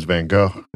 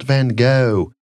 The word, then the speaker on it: Van Gogh: So if you're listening and you're going, it's not Van Gogh, it's Van Gogh.